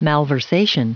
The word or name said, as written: malversation